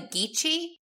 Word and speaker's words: Geechee: South Carolina has a mix of cultures influenced by the colonial history, including Native American, European, and African, and also the Geechee cultures. Am I saying that correctly? – Yep.